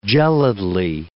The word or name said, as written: gelidly